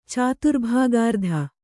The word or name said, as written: ಚಾತುರ್ಭಾಗಾರ್ಧ